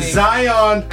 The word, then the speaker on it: Zion: Zion. You said it right.